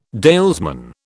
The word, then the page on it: dalesman: British English